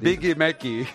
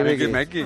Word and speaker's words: Big Mac: Es Biggie Mecky. – Biggie Mecky, ¿eh? – Biggie Mecky. O sea que esto es con acento en la E y sin la I.